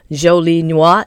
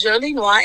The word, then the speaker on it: Jolie Noir: Yes, you said it correctly. – Don't stop for me. – we can't fault anybody because it's French. So, you know, we can't expect for Americans to pronounce it correctly. We didn't pronounce it at first correctly. So, but yes, it's Jolie Noir, and it means pretty black in French.